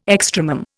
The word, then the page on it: extremum: Indian